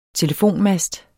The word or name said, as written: telefonmast